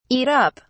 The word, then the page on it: eat up: British English